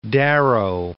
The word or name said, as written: Darrow